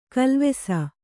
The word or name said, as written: ಕಾಲವಶ